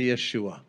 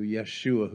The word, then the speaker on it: Yeshua: Everybody say Yeshua. Say it like our Hebrew friends do, Yeshua, who came into this world.